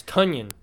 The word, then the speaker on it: Tonyan: It's Tonyan. It's pronounced like Funyan with a T.